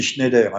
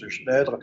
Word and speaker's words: Schneider: ça s'écrit Schneider, hein, mais ça se prononce Schneider, et, et autres, notamment dans les, maîtres de forge qui euh, avaient des politiques sociales très, très avancées euh, pour l'époque.